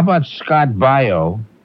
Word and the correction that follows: Baio: Oh, it's Bayo. See, I'm pronouncing his name wrong. That's why you got confused. It's Scott Baio, B-A-I-O.